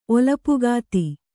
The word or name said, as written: ಒಲಪುಗಾತಿ